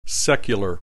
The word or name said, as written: secular